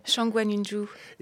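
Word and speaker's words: Shang Guanyunzhu (上官云珠): Shang Guanyunzhu.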